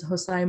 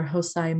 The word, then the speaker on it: Hosai: Um, my handles are the same on all platforms, which are it's my full name is Hosai Mojadiddi, um, but my uh, handles are Hosai Mojo, which is spelled H O S A I M O J O.